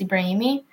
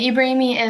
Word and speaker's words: Ibraimi: They would tell me to change how they pronounce my last name because my last name is Ibraimi, and so is theirs. And Ibraimi is like very Muslim, like it's one of the prophets and stuff like that.